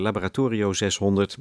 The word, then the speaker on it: Laboratorio 600: De harpiste van Laboratoria, nou zeg ik het weer. Dat komt omdat het gaat over een harpiste, dus dan is het een Laboratorio 600, zo moet ik de naam uitspreken.